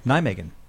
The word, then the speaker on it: Nijmegen: Well, speaking about not awful records, we got a comment from somebody overseas who said that the Baseball Project show in Nijmegen, I'm not sure if I'm pronouncing that right. – Nijmegen.